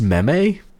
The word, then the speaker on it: meme: And I actually think it's called, it's actually pronounced meme. – Meme. Yeah, that makes, well, I say meme as well, but that's because I've read it. – Kind of rhymes with jeans.